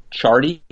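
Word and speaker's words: Ciardi: Mark Chardy. It's spelled C-I-A-R, but I think it's Chardy.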